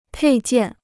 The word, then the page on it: 配件: pèi jiàn